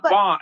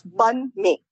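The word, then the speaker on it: bánh mì: So the best pronunciation, so there are actually two pronunciations. One of them is the South Vietnamese way, which might be a little bit easier for you guys because it's close to what you see when you look at the word bun mi. – Yes. – And that's the way it's pronounced, bun mi, like bun, hamburger buns, bun mi.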